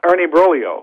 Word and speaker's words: Ernie Broglio: Ernie Broglio, that's right. – He was a pitcher for the Cardinals, and the Cubs wanted him, and they were willing to give up Lou Brock for Ernie Broglio.